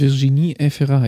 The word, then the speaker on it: Virginie Efira: Virginie Efira. – Richtig, Virginie Efira. Ich hätte das jetzt nicht mal so aussprechen können.